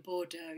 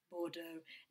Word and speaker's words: Bordeaux: How was Bordeaux? – Bordeaux. You have to say it like that. Bordeaux.